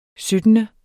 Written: syttende

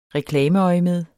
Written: reklameøjemed

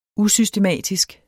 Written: usystematisk

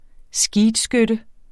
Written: skeetskytte

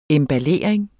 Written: emballering

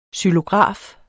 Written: XYLOGRAF

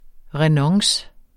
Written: renonce

